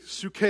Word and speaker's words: psuche: The word is suke in the Greek. Suke is how you pronounce that.